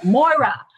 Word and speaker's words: Moira: And I mean, the whole cast, I spent a week trying to talk like Myra. I'm probably mispronouncing her name, but I Moira. – Love that show. Moira.